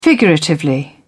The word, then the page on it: figuratively: British English